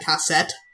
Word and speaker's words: cassette: You know, when I was taking French in high school, I found out that in French, they would say, they would advertise cassette using the letter and number K and 7, because in French you pronounce that cassette.